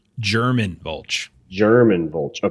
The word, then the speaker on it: German Vulch: Everett will walk over to, I think it was Herman Vulch. – German Vulch. – Okay. Yeah. So it is pronounced as like the language.